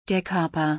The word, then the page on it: der Körper: German